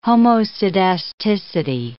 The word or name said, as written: homoscedasticity